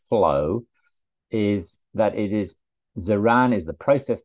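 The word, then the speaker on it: Ziran (自然): And then next up is Ziran. I hope I'm pronouncing that right. – Very good pronunciation. Yes, Ziran.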